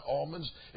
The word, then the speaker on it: almonds: It's not almonds, it's almonds.